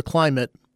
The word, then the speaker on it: Klymit: What I bought, after seeing it on Facebook, was the Climate, that's spelled K-L-Y-M-I-T, Static V Lux.